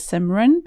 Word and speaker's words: Simran: It is Simran, but it is spelt Simran.